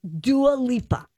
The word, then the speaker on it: Dua Lipa: Oh, I was going to say it right. – Dua Lipa. – Dua Lipa. – There you go.